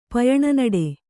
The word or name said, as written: ಪಯಣನಡೆ